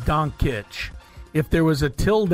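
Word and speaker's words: Dončić: There's a little apostrophe mark over the second C, which tells me to pronounce that with the ch sound. – So that's why I go Doncic.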